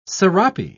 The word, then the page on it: sarape: British English